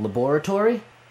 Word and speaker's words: laboratory: I realize they may pronounce that differently on the East Coast, but in California, where the lab is located, it is laboratory.